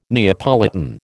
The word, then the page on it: Neapolitan: American English